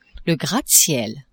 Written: gratte-ciel

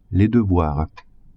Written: devoirs

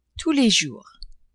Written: tous les jours

